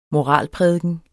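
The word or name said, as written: MORALPRÆKNERNE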